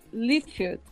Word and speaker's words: Liefeld: Ó, pergunta do garotinho do Rodrigo Salazar uma HQ desenhada pelo Liefeld.